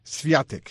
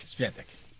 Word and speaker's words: Świątek: Pero te digo una cosa, y al mismo tiempo, Iga Świątek, así me gusta la pronunciación polaca, es la más... mi productor me dijo, es Iga Świątek. – Świątek.